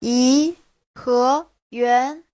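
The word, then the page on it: yiheyuan: yi2he2yuan2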